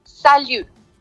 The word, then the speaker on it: salut: Entonces, hola es salut. Como cuando le dicen a, cuando alguna persona estornuda y ustedes le dicen salud, vamos a tratar de hacer ese sonido, pero al final vamos a cerrar la boca y pronunciar como si fuera una U y una I unida. Entonces sería salut.